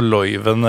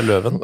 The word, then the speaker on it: Leuven: Ja, ikke noe Loiven eller noe sånt, det er Løven.